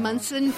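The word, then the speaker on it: Monson: I am Kim Munson.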